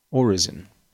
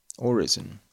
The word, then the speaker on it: orison: Pronounced orison. Orison, like Roy Orbison, but just an orison.